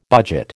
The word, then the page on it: budget: American English